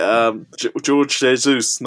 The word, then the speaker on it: Jorge Jesus: And along similar lines, we had a question which said, besides Guiaro, I always get Jorge Jesus, no? Is the right pronunciation in Portuguese? – Yeah, just J, not H.